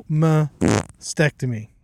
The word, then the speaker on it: mastectomy: Double mastectomy.